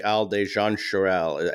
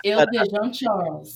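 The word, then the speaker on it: Isle de Jean Charles: You know, I wanted to touch base with you about the Isle de Jean Charel. I think I'm pronouncing that in the Louisiana way. – Isle de Jean Charel.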